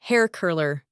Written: hair curler

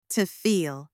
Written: to feel

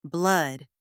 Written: blood

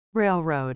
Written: railroad

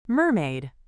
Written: mermaid

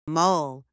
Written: mole

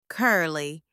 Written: curly

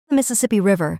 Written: Mississippi River

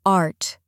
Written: art

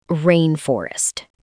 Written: rainforest

